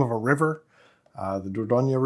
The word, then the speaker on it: Dordogne: Is a very commonly known Dordogne. – Gesundheit. – No one would pronounce this as Dordogne.